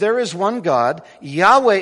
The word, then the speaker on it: Yahweh: Now, how do you pronounce, in Hebrew at least, the personal name of God? Most scholars today, I think, would say it's Yahweh. It's Yahweh.